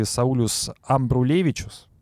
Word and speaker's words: Саулюс Амбрулевичус: И вот Элисон Рит и Саулюс Амбрулевичус. Правильно? – Да, здесь правильно. – Ну, просто фамилии Рит очень сложно э, перепутать э, ударение.